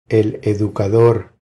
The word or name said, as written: El educador